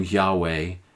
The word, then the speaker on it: Yahweh: Some pronounce this name Yahweh, and most Christians pronounce it Jehovah.